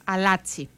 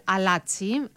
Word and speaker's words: Αλάτσι: Πρόκειται για τον Σαχίντ Ελ Αλαζάι, ή τον λέω λάθος, Αλάτσι, μάλιστα. Σαχίντ Ελ Αλάτσι, αυτό είναι ακριβώς όπως προφέρεται το όνομα του.